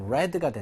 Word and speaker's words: read: 스펠이 똑같습니다. 철자가 똑같은데 read, read, read. 이렇게 3단 변화를 해요. 그래서 PP도 스펠은 똑같다. 하지만 발음은 red가 된다.